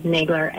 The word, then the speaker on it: Nagler: It's Rebecca, R-E-B-E-K-A-H. Last name is Nagler, N-A-G-L-E-R.